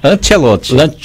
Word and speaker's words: Ancelotti: Ancelotti. – Ancelotti, a pronúncia. É escrito Ancelotti, mas é pronunciado Ancelotti.